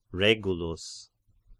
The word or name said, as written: Regulus